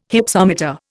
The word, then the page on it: Hypsometer: Indian